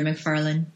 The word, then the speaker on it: McFarlane: I said McFarlane, and it's L-A-N-E at the end. McFarlane, how do you say that, Bay? – It kind of gets all mushed together, McFarlane.